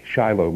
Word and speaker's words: Shiloh: Shiloh, maybe you understand, is pronounced Shiloh in Israel.